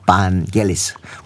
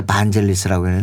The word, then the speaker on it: 반겔리스: 반겔리스. 우리는 그때 당시에 반젤리스라고 했는데 네. – 본인이 우리 내 안에서 발음이 네. 반겔리스라고 시정을 했어요.